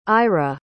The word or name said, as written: ira